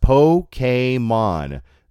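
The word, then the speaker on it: Pokémon: Pokemon is pronounced Pokemon. There is no E sound in the name.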